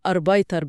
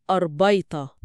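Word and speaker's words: Arbeiter: مش بتتنطق أربايتر براء واضحة، النطق الطبيعي هو أربايتا.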